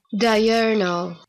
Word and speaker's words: diurnal: Diurnal.